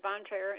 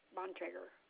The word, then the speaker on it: Bontrager: Okay, my name is, first name is Carol, C-A-R-O-L. – Thank you, and the last name? – My last name is Bontrager, and it's spelled with a B, B as in boy, O-N, T as in Tom, R-A, G as in girl, E-R, Bontrager.